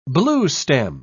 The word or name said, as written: bluestem